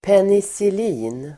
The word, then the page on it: penicillin: penisil'i:n